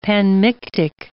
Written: panmictic

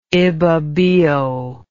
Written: ibibio

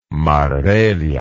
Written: marbella

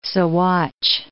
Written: Sawatch Range

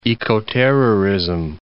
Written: ecoterrorism

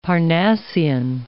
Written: Parnassian

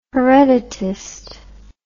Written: hereditist

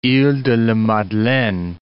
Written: iles de la Madeleine

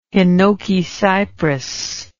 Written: hinoki cypress

